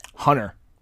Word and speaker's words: Hunter: I never say Hunter correctly. Never say it correctly. – How do you say it? – Hunter.